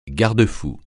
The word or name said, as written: garde-fou